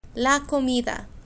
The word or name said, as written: la comida